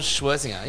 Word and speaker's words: Scherzinger: Nicole Scherzinger.